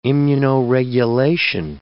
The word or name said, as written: immunoregulation